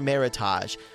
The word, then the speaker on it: Meritage: And also start saying it correctly, because if you say Meritage, it's just, you know, like trying to get fancy, like you go to Target and you get your Meritage. We don't need any of that. You could just call it Meritage. Remember, it rhymes with heritage.